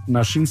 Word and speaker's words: Naxin: Nashin se escribe Naxin y es en mazateco que significa venadito.